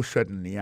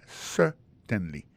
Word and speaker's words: certainly: Alors, en parlant de accent, regardez bien pour un mot certainly, accent tombé sur le premier syllabe là. Yes, certainly.